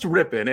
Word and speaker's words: Rypien: So let me, from a tenured guy like myself, it's pronounced Rypien. And the only reason I make that correction is because he is the son of Super Bowl champion Mark Rypien, who won it in 1991 with formerly known as the Washington Redskins.